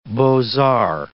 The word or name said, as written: beaux arts